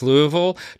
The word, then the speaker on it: Louisville: I would like to note, by the way, that you're pronouncing it correctly as Louisville.